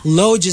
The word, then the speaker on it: loge: We pronounce it lodge, pero alam ko it's lodge. L-O-G-E.